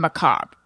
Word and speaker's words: macabre: But anyway, I remember one time he's like, why are you pronouncing the R in macabre? Macabre. I think I was saying like, I was trying to give the R like just a, I see you R, macabre.